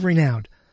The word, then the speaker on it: renown: You don't put a D on that, do you? He's renowned. He's a man of renown.